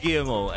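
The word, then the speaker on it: Guillemot: So kailangan kabisado yun yung pronunciation niya. Is Guillermo.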